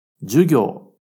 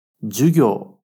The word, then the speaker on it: 授業: Jugyo. Jugyo.